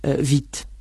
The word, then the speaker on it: vite: Sí, por ejemplo, vit, rápido. Vit se pronuncia exactamente como si no existiera la E final.